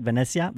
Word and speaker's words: Venezia: Hey, so switching gears a little bit, our other favorite team in Serie A is Venezia because of the America. Did I say that right? Venezia? – Venezia, sure.